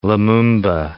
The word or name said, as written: Lumumba